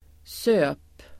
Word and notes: söp: [sö:p]

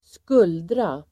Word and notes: skuldra: [²sk'ul:dra]

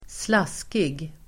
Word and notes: slaskig: [²sl'as:kig]

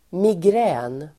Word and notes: migrän: [migr'ä:n]

